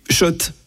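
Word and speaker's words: Schott: Oui, Shot et non, pas Scott. Scott. Ça fait des années qu'on prononce mal la marque.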